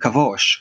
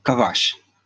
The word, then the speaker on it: Kavash: I know in uh, in Discovery they it has been uh, um, pronounced Dom Kavosh or Dam Kavosh, but actually uh, in the game lore, in the intro, it is pronounced Dom Kavash like a like a use like a, like dumb kabosh yeah.